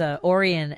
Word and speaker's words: Orion: You know, having a station has Orion as you know, one of our icons, yes, and legends here. It's Orion, like the constellation.